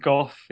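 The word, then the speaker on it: Gough: So it's Darren Goff. It's pronounced with a strong F as opposed to, I think, possibly go or go, as you were interpreting it. – So it's G-O-U-G-H, like Vincent Van Gogh, maybe.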